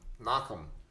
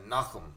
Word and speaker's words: Nachum: Nachum. – Great. You got it. Great. – Nachum.